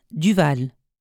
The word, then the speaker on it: Duval: Christine Duval.